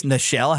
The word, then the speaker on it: Nichelle: So her death completely almost got forgotten on the same day that she died because, you know, and I always know, I think it's Nichelle, how you say that, isn't it? – It is. Yeah.